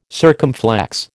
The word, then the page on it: circumflex: American English